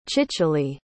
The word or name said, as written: cicily